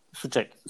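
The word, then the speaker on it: Souček: Ben hani bu programda galiba biz Sos, e, Sosek diye okunuyordu? Suçek. Suçek. Suçek.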